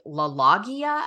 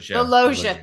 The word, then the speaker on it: LaLoggia: which is Frank LaLoggia. I don't think I've LaLoggia. – Thank you. Yeah. I don't think I've ever said his name properly in my life.